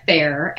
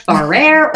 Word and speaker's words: Farrer: Okay, so my name is Rhonna Fair, and I have to say my name usually because people, when you read it, it looks like Rhonna Farrer or Rhonna Farr or whatever. So when people finally hear me say it, they're like, oh, that's how you say it. So Rhonna Fair, it's a little bit different, but I am a mother of three.